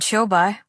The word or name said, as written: Qiubai